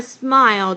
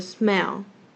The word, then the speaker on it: smile: 好 just smile 这边的 just 它的那个 t 的音没有跑出来，而是 j-u-s，然后它直接连那个 s-m-i-l-e just smile 等于是它把 t 直接省略掉 just smile 那这边有要注意的另外一个点，很多人 smile 都没有把嘴巴张大，会念成 smell，就变成闻，而不是微笑，而不是笑了。